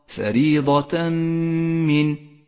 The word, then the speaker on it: فريضة من: والآن حكم الإدغام بغنة، عند قوله تعالى فريضة من الله، فريضة من، التنوين وبعده حرف الميم، نسقط التنوين من اللفظ، ولذلك هو رمادي اللون في مصحف التجويد إشارة إلى عدم نطقه، نشدد حرف الميم الذي بعده ونقف عليه بغنة زمنها حركتان، ولذلك لونت الميم باللون الأخضر إشارة إلى موقع الغنة، فريضة من.